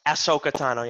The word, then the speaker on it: Ahsoka: Ahsoka Tano.